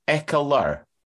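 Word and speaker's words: Ekeler: There's an extra syllable in there. Eckler.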